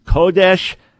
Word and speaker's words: Kodesh: The Ruach HaKodesh, commonly mispronounced in Messianic circles as Ruach HaKodesh. Now, the accent's on the first syllable. So everyone just say it out loud with me five times. Kodesh, Kodesh, Kodesh, Kodesh, Kodesh.